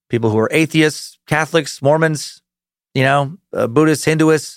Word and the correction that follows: Hindus: Is that how you say it? Hindus, there we go. Why did I say Hinduists?